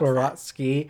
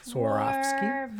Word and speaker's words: Swarovski: I never know how to pronounce Swarovski.